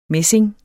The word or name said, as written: messing